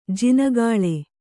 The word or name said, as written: ಜಿನಗಾಳೆ